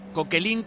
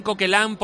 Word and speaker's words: Coquelin: Nos ayuda a los oyentes desde Francia, en este caso con la pronunciación, se dice Coquelin, Coquelán, porque tú, porque te. Dices. Y otro dice que no.